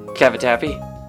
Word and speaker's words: cavatappi: Cavatappi.